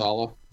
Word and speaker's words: Saleh: Saleh.